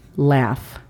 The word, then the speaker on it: laugh: La pronunciación fonética se escribiría L-E-A-F, laugh. Nota que si has visto la palabra escrita correctamente, se escribe L-A-U-G-H. Sin embargo, la G-H al final hace el sonido de la letra F en español y por eso escribimos fonéticamente L-E-A-F, laugh.